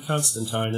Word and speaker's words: Constantine: Oh, I think even John Constantine is in there, and I'm going to say Constantine's name probably 200 to 300 times an episode because that's how it's pronounced. Not teen, but time.